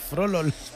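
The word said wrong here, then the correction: Froholdt: Bueno, Froholt. – Froholt. – Froholt. – Está muy bien pronunciado. – Froholt. – Pero es que la H intercalada.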